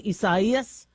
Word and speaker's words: Isaias: It's actually pronounced Isaias. And so many media outlets, including myself, have had to correct herself. I know the Latino pronunciation is Isaias, but we've been calling it Isaias when that name was used in the past. I do appreciate the appropriate pronunciation.